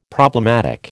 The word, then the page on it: problematic: American English